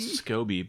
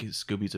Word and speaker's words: SCOBY: I always call it Scoby, but I think Scooby's a better way to pronounce it.